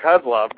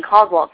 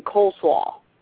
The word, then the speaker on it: Kozlov: I've actually got another silly joke about Kozlov that OVW faithful know about, and that's listening to one of the announcers has a problem pronouncing Kozlov for some reason. And he says it in such a way that it sounds like he's saying coleslaw.